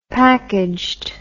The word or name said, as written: packaged